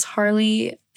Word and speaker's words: Harleigh: My username is Harley A. Walker. Harley is spelled kind of funny. It's H-A-R-L-E-I-G-H.